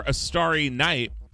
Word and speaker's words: Astarinite: Before that one, I had a request for A Starry Night, which is spelled A-S-T-A-R-I-N-I-T-E. That's two words.